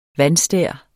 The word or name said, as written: vandstær